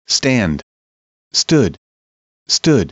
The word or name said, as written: stand stood stood